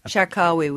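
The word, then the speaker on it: Charkaoui: forgive my pronunciation, Shakawi? – Shakawi, we call it, yeah.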